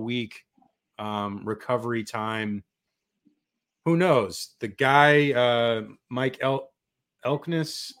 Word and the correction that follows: Elko: how do you say his name? The coach for Duke? – Elko. – So Elko, he said on his coach's show last night that he could go potentially.